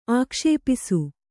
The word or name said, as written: ಆಕ್ಷೇಪಿಸು